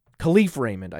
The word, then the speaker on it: Kalif Raymond: Khalil Raymond or Kalif Raymond, Kalif Raymond, I apologize to Kalif Raymond and his family for mispronouncing his name there.